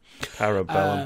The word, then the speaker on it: Parabellum: Rainbow Six is getting two new defenders and a new map in the Year 3 Season 2 update, Operation Palmer. I think I said that right. – Parabellum. Um, parabellum. Yeah, Par- I was getting to the Bellum part. I knew that part, but par, Palum Parum Para.